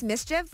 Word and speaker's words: MSCHF: I think it's pronounced mischief, but it's spelled M-S-C-H-F.